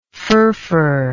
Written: furfur

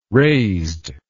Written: raised

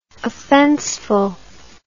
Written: offenseful

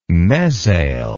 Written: mezail

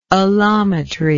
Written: alloiometric

